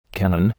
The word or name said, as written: cannon